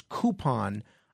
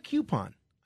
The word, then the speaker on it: coupon: One of the things that I said this week that really irked a lot of people was my pronunciation of the word coupon. Apparently, the proper pronunciation is coupon. I like coupon. I feel like it's rolls off the tongue a little bit better.